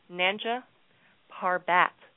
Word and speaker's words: Nanga Parbat: This slaughter, it took place at the base camp for Nanga Barak Peak. And I better say that properly. Nanga Parbat Peak. My apologies.